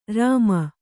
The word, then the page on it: ರುಮೆ: kannada